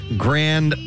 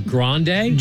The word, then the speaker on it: Grand: It says, pronounce Plaza Grand as in grand prize. So, how would I? – Maybe grande? – G-R-A-N-D is how it's spelled.